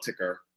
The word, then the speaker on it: TKR: I prefer to call it Ticker.